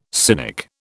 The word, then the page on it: cynic: British English